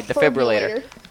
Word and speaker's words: defibrillator: When I wanted the flibberator for Christmas. Wait, am I saying that right? Hold on. – The defibrillator. – Okay, defibrillator.